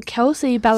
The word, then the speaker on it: Kelsey Ballerini: Cliche? – Oh, Kelsey Ballerini.